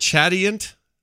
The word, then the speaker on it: chatoyant: Chatoyant signet. Chatoyant. – Chatoyant. Well, in French, it would be chatoyant.